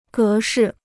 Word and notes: 格式: gé shì

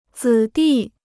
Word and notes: 子弟: zǐ dì

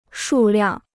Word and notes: 数量: shù liàng

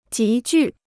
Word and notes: 急剧: jí jù

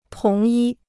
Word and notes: 同一: tóng yī

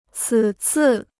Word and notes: 此次: cǐ cì

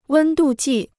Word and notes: 温度计: wēn dù jì

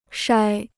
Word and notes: 筛: shāi

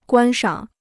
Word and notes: 观赏: guān shǎng

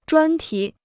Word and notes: 专题: zhuān tí

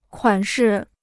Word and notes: 款式: kuǎn shi

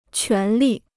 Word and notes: 权利: quán lì

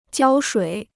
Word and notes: 胶水: jiāo shuǐ

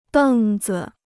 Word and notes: 凳子: dèng zi

